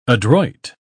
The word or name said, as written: adroit